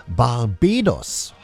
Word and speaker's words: Barbados: Barbados ist richtig, aber in Englisch nicht. Falsch ausgesprochen, ne? Barbados heißt diese Inselformation.